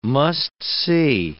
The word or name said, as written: must-see